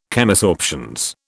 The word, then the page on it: Chemisorptions: British English